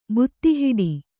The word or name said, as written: ಬುತ್ತಿಹಿಡಿ